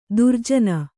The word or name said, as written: ತರ್ಜನೆ